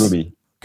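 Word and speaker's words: Roumie: Did I say it right? – I thought it was Rumi. – Is it Rumi? Let me look him up again here. It's on my page. Yeah, it is Rumi.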